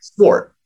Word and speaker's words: Swart: So I always tell people it's Wart with an S in front of it. – Okay. – So Swart. But people put Z's on it. People put, you know, S-C-H and Schwartz.